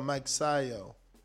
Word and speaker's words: Magsayo: Is it Magsayo? – Yeah. – Oh, Magsayo. Maxayo.